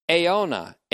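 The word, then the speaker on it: aiona (αἰῶνα): Eis, E-I-S, tone, T-O-N, aiona, A-I-O-N-A, long O-N-A.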